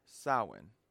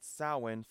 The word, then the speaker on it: Samhain: In short, the MH is kind of like its own letter, but they don't have the letter W, so we have the MH, and that's how you get Samhain from that spelling.